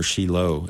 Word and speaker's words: Shiloh: You know, Vic and I went to Shiloh, or Shiloh is better pronounced.